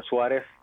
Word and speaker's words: Suárez: Y también tengo un canal de YouTube, estoy bajo mi nombre, Emilio Suárez. Y pueden encontrarme ahí también, Suárez, con acento en la A y termina en Z. – Okay. – SUA acentuada, RZ, se me olvidó de repente.